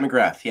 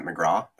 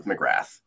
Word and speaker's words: McGrath: If I was a good, Irish boy, I'd say Matt McGraw. That's like the correct way of pronouncing it, I guess. – Yeah. – But no, I use the, bastardized North American version of McGrath.